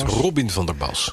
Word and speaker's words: Robin van der Bas: Ja, we hebben hem al 5 keer verkeerd uitgesproken. Hij heet echt Robin van der Bas.